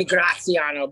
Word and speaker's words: Graziano: So my family's from East Boston, so we're not bougie. Like, it should be Graziano, but we kept the Boston in it.